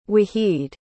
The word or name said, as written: wahid